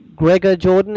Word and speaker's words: Gregor Jordan: You mentioned your husband, Australian director, Gregor Jordan.